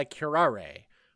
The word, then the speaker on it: Curare: So Commissioner Babs and her husband Sam are in Gotham Park for a walk when they're attacked by Curare. Rhymes with Ferrare.